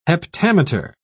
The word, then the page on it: heptameter: British English